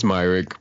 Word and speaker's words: Myrick: It's Myrick, but a lot of people say Myrick.